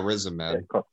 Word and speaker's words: Riz Ahmed: And I think Riz Ahmed, is that how you say his name? – Yeah, Riz Ahmed.